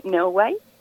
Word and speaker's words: Neowise: Y nos vas a comentar en esta mañana sobre el cometa Neoways, si lo estoy pronunciando bien, si no, tú corrígeme por favor, ¿de qué se trata? – Sí, bueno, estas palabras así extrañas es válido pronunciarlas como podamos. Okay. pues sí, yo les quiero hablar de Neowise, eh, que está captando nuestra atención desde hace ya varias semanas.